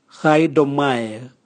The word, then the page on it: gaidomwae: Raga